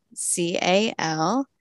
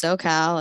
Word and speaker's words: SewCal: It's spelled SewCal. Uh-huh. So it's SewCal, like Southern California, but I got the sewing in there.